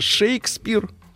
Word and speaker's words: Шекспир: Шекспир, так uh-huh.